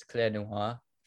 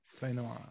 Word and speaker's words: Clé Noir: That's two ways. I think people like saying it when it actually has the French accent in it. It's Clé Noir. – Clé Noir, okay. – Yeah, which is pronounced Black Key. But I think Clé Noir is like the actual spelling of the whole thing.